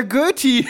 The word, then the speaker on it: Goethe: Goethe, der Goethe! Ja, oder der Goethe. Braucht man gar nicht Englisch aussprechen.